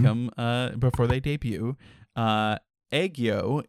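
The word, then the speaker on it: aegyo: it's kind of like egg yolk. Like eggyo. It's A-E-G-Y-O. Eggyo.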